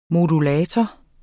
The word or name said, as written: modulator